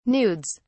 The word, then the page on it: nudes: British English